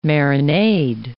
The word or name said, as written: marinade